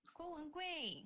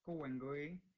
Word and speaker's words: Guo Wengui: Guo Wengui. – Guo Wengui. – Smile's Chinese name. – Mm-hmm. Guo Wengui.